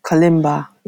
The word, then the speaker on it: kalimba: I believe it's the klimbe. – Kalimba, yeah.